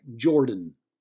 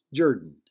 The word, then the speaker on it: Jordan: By the way, I noticed when foreigners pronounced the name of the stadium, beginning of the word was something that sounded like Jordan, as in Jordache, jeans. A true Auburn fan knew it was really pronounced Jordan, as in Shug Jordan.